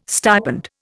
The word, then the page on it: stipend: Indian